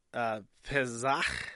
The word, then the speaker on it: Pesach: Pesach.